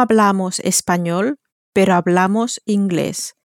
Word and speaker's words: hablamos: We speak is spelt H-A-B-L-A-M-O-S, so it ends in an S, therefore follows the onus rule. The onus rule tells us that words ending in N or S receive the same stress as words ending in a vowel, and so they're stressed on the penultimate, on the next to last syllable.